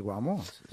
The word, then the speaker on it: Guamo: Entonces, ¿qué Bésame, el Guamo? ¿Se dice así? – Chao, Ana. – Sí, claro, el Guamo.